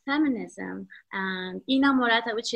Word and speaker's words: Inamorata: So for example, Inamorata, is that how you pronounce that? – Yes, Inamorata, yes.